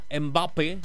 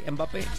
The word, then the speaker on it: Mbappé: Dice, ese vato es francés, es francés, es François, se pronuncia Mbappé, así, Mbappé.